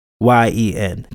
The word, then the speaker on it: Nguyen: Ryan Lee, I never know how to say this name, Nguyen, it's Nguyen, 29,